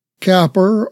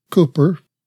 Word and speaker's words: Cowper: Cowper or Cooper, as different people pronounce it.